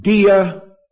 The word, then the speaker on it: diatheke: It is pronounced diatheke. D-I-A-T-H-E-K-E. Diatheke, which means covenant.